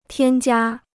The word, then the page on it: 添加: tiān jiā